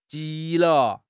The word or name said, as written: ji2 le5